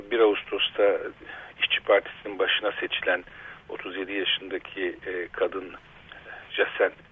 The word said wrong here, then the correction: Jacinda: nasıl telaffuz edilir bilmiyorum, Ardern soyadı. – Jacinda diye okuyor.